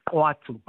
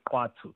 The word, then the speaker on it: Khwattu: It's called Kwatu, but it's spelled Khwattu, but it's pronounced Kwatu.